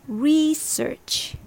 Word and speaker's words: research: Pronunciation number two, stress on the second syllable. It's more common in British English, especially when it's used as a verb. Research. Here, r sounds more like r, like schwa, and the second syllable, search, is the same as before but emphasized. So it's research.